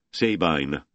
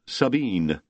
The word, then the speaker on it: Sabine: "Ever newcomer says it like you just did. Sabine, no accent, rhymes with wine. Ancient Greek. Us locals call it Sabine. Heavy accent, last syllable. Rhymes with queen."